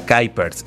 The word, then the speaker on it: Kuypers: Sí, porque, por ejemplo, en la parte de la escritura es Kuypers, ¿no? Con Y. Ah, exacto. – Ya la pronunciación, por ser belga, Kuypers.